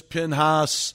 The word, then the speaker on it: Pinhas: and it's pronounced different ways. Phinehas, Pinhas, the Bible hero.